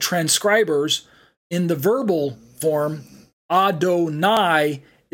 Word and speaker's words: Adonai: So on the right side of the screen, we have Adonai, pronounced as Adon plus I, and it's a title reserved exclusively for God. And with the little dots and dashes underneath, we get that pronunciation Adonai, or transliterated as A-D-O-N-A-I.